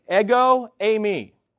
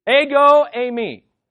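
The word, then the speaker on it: Ego, eimi (ἐγώ εἰμι): Just as Yahweh is important, here's how you say it in Greek. Ego, eimi. Let go of my ego. Ego, eimi.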